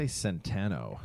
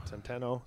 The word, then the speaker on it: Centeno: And we saw Centeno. I'm not 100% sure how to pronounce it. – I think they say Centeno. – Centeno. So almost like Santana.